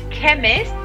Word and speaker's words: chemist: The chemist, que es la farmacia. Uh-huh. Chemist. Y cuidado porque se escribe con CH, ¿vale? CHEMIST. Chemist.